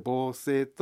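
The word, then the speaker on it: 防錆: B はですね、防性フィルム、これは漢字で書きますと防ぐ錆と書いて防性と読みますが、はい、これは私ども 1969 年にですね、世界で初めて、商品化した商品でございます。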